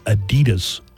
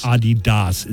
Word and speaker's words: Adidas: Americans, that's all of us, are pronouncing Adidas wrong. The shoe company, Adidas. – Adidas. – That's not how it's pronounced. – Uh-oh. – It's supposed to be Adidas.